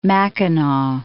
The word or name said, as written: Mackinac